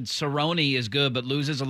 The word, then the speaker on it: Cerrone: By the way, somebody said his last name is pronounced Cerrone. – I know it's Cerrone. I just don't call him Cerrone.